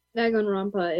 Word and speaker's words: Danganronpa: And then I only got to see half of Danganronpa, I think is called, or well, pronounced. I don't really know how to pronounce it. – Danganronpa is how you pronounce it.